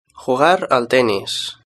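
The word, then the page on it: jugar al tennis: Spain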